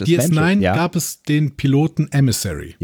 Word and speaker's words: Emissary: Emissary. – Emissary. Genau, so wird es ausgesprochen.